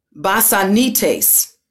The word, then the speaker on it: basanistes: The Greek word for torment is basanites. Basanites. B-A-S-A-N-I-S-T-E-S. Basanites. I'm pretty sure I just crucified the pronunciation, but you get it, right?